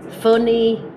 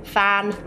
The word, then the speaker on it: fun: Bueno, mucha gente se confunde con fun, funny, que se dice también fan, que no se dice así, se dice fun, funny.